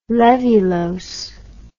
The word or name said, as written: laevulose